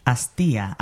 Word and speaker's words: astilla: Okay, now remember how the double L is pronounced. – Astilla.